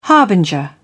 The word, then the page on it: harbinger: British English